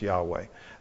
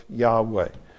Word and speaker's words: Yahweh: But several generations ago, scholars took that apart and said, it's probably better pronounced Yahweh. That's what most people pronounce it today, but we cannot say for sure that that's how it's pronounced.